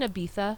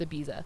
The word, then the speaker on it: Ibiza: but she goes, "We're here in Ibiza." – Oh no. – And I know that is how you say it, but as a white American, it's Ibiza.